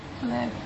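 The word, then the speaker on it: chleb: It's not how you say it. – Which? The schlep? How do you say it? – Kleb. – Huh? – Kleb. – Okay. Kleb. Okay.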